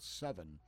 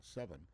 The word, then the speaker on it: Sevin: There is a chemical granular chemical out there called Seven. And it's spelled S-E-V-I-N, Seven.